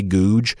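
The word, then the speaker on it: Gogue: I believe his name is Jay Goode. I think that's how you say it, Jay Googe. So there was a YouTube video that the Auburn president Googe shared with incoming freshmen to Auburn University.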